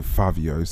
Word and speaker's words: Fivio Foreign: I know some people pronounce it Favio, but it's written Fivio.